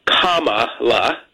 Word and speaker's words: Kamala: Her name is pronounced Kamala, comma with a la at the end, Kamala Harris, and she'll be quick to point it out and correct folks who don't get that right, very sensitive to that.